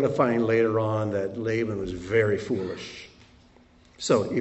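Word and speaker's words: Laban (לבן): Laban, this is how it's spelled, and I'm going to not use the final form, but it's Lamed Beit Nun, Laban.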